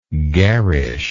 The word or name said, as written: garishness